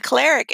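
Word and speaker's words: choleric: The cleric, and it's spelled C-H-O-L-E-R-I-C. I have a Midwest accent, so it may come out a different way.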